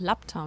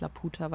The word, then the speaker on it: Laputa: Das Ist doch Lapta und nicht Laputa, weil Laputa ja.